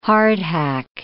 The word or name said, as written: hardhack